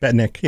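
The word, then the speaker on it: Betnikh: And then, like, of course, there's the beautiful beach places that I've been exposed to through Elder Scrolls Online, like Ordon, or I think it's Beatnik. Is that how you pronounce it? Or Betnik? – Betnik, yeah. Yeah.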